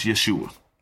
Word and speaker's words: Yeshua: But either way, the correct pronunciation is Yeshua.